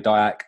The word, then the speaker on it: Diack: But yeah, as you said, my name is David Diack.